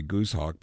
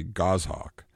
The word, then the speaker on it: goshawk: You want to say goosehawk, but there's no G-O-O-S-E in it. It's G-O-S-H-A-W-A-K, and it's really goshawk.